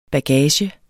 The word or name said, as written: bagage